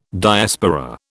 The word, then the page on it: Diaspora: British English